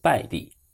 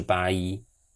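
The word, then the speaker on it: Bailly: 讲到巴伊，先说说他的名字，B A L L Y，按照英文发音应该译作拜利，但按照法文发音则是巴伊。巴伊说的是法语，所以我更习惯称他为巴伊。巴伊目前在曼联的境况是非常令人遗憾的。但也完全可以预见。二零一六年夏天，名不见经传的巴伊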